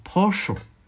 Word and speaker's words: Porsche: 他们说保时捷你不能这样读啊，要读什么 Porsche，哎，我觉得真的没必要读这纠结这种发音嘛，因为连创始人，比如说 Lamport 说你爱怎么读怎么读啊，所以呢。